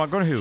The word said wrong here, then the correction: McConaughey: Sí. – Un futbolero, le gusta. Hay que ir corrigiendo la pronunciación. – McConaughey.